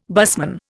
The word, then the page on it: busman: Indian